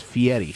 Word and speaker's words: Fieri: Oh, that's how you pronounce his name is Fieri. – Fieri.